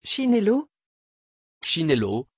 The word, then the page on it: chinelo: French